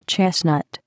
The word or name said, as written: chestnut